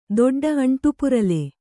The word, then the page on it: ದೊಡ್ಡ: doḍḍa